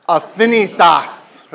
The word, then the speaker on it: Affinitas: Remember, there's a correct way of saying this to make it stick. Ready? Affinitas. That's right. Again, don't get the tas in there. All right? Affinitas means relationships in Latin.